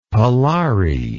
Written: polari

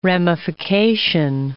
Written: ramification

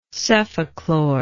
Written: cefaclor

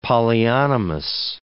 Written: polyonymous

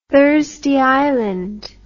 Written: Thursday Island

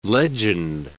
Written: legend

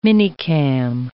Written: Minicam

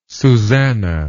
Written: susanna

